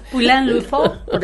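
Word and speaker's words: Pouilly-le-Fort: También tenemos que el 5 de mayo de 1881 en Francia, el químico francés Louis Pasteur, en una granja de, disculpen, pronunciación, Pouliliforn. – Ponele. – Corregime, me puede corregir. – Poulain-Lefort.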